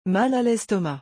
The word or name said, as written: mal a lestomac